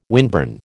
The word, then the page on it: windburn: American English